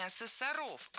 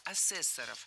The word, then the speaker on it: асессоров: Лучше сказать не так, не асессоров, а асессоров. В слове «асессор» ударение на втором слоге сохраняется во всех формах.